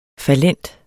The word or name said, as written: fallent